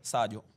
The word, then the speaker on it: Sadio: Come si dice? Sadio, Sadio? – Sadio.